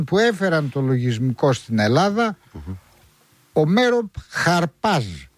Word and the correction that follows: Αρπάζ: Τι Χαρπάζ, Αρπάζ. – Αρπάζ. Έτσι προφέρεται, Αρπάζ.